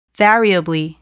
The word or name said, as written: variably